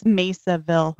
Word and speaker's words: Mesaville: but at the end of the episode it's pronounced Mesaville. – It's Mesaville. – Or Mesaville. – M-E-S-A-V-I-L-L-E. – Actually, it's Mesa, isn't it? – Yeah, just Mesa.